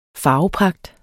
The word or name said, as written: farvepragt